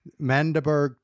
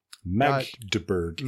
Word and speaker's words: Magdeburg: Mandeberg Train. – Magdeburg.